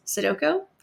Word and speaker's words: Sudoku: Sudoku? I think the proper way to say it is Sudoku.